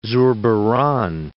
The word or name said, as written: Zurbaran